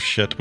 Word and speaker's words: Pschitt: That shit, for those of you listening, is spelled PSCHITT exclamation point. – Yep, silent P, baby. Shit. Like shit. Like psychology or anything else, you know.